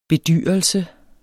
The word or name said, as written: bedyrelse